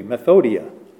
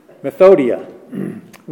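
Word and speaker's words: Methodia (μεθοδεία): Wiles, Greek, methodoia. Methodia. Got an extra syllable in there. Methodia.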